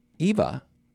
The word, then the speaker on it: Eva: Now, I always thought Zsa Zsa Gabor was in Green Acres, but it was her sister, Eva Gabor. I think it's EVA, Eva. You always pronounce it Eva? Well, you've always heard it wrong. – Okay. – I believe it's EVA.